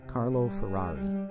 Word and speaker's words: Carlo Ferrari: Now I will pronounce his name with my accent. Carlo Ferrari.